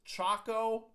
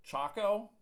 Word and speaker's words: Chaokoh: I'm, I don't know how you pronounce it, but I think it's pronounced Chaokoh Coconut Milk. It's C H A O K O H. Chaokoh it's Chaokoh coconut milk, Jamie.